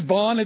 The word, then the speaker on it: Bond: My name is Bond. It's pronounced like James Bond, so that's why he said James Bond.